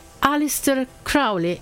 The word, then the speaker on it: Aleister Crowley: Il personaggio è Aleister Crowley.